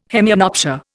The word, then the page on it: hemianopsia: Indian